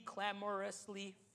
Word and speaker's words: clamorously: To boast, to rave, to shine, to celebrate, and look at this, to be clamorously foolish. Sorry, it's my third language. To be clamorless, oh my, how do you say it, Sophia? Clamorously foolish.